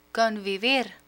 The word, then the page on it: convivir: Spanish